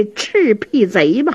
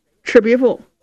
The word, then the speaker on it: 赤壁赋: 写着一首《赤壁赋》。冯君衡一瞧，你这赤壁贼写的不坏呀，来来来来，劳你大驾，我这有把扇子，这面是素的，你也给我写上这赤壁贼吧。雨墨不敢公开的顶了，小声嘀咕，连字都不认识，赤壁赋念成赤壁贼了，真不是东西。嗯，真不是东西，这句话呀声音稍微大这么一点，这冯君衡好像听见了，一歪头，严茶伞呢就给岔活过去了。